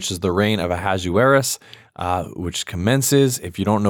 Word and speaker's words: Ahasuerus: I mean, now that I see the name, I'm like, oh yeah, I could totally see how it's pronounced Ahasuerus.